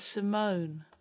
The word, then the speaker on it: Simone: And it's not Izzy before you say Izzy. It's like that, no. – Okay. – It is Simone.